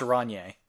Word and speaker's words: Rainier: Sometimes I'll see Rainier in a bottle, and that blows my fucking mind. – Oh, yeah, that's weird. I believe it's pronounced Rainier, but sure.